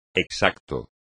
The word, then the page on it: exacto: espagnol